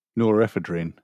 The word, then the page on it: norepinephrine: British English